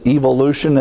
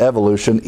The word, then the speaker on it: evolution: Therefore, they go over to other forms such as evolution, as the British pronounce it. I like the British pronounce it. Not evolution, evolution, a better term.